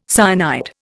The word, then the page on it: cyanide: Indian